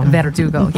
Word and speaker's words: Verdugo: Well, see, I don't even say my, I have a Spanish last name and I don't even say it right. – Verdugo. – Verdugo. Yeah, there you go. I think I said it right this time.